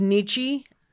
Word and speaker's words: Nietzsche: I know it's Nietzsche.